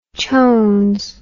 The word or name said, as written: chones